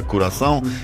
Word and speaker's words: Curaçao: Będziemy oczywiście odwoływać się do tego, że nie ma biało-czerwonych, a jest na przykład reprezentacja Curaçao, jak podobno się poprawnie wymawia nazwa tego państwa,